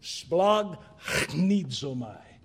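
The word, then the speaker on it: Splag chnidzomai (σπλαγχνίζομαι): This word that is an untranslatable word, splachnidzomai, hard to say it, Splag chnidzomai. You go from a gamma to a chi.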